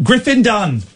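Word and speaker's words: Griffin Dunne: And then Griffin Dunn. So it's 2 syllables and 1 syllable.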